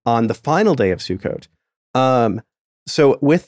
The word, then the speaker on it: Sukkot: or I can't remember how to pronounce it. It's Sukkot? – Yeah, Sukkot. – Sukkot.